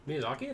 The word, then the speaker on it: Miyazaki: I think I mispronounced his last name. – Miyazaki, that's right.